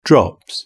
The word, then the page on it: drops: British English